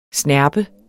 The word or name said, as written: SNERP